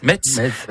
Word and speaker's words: Metz: Il s'agit bien sûr du TZ qui finit mess. Metz.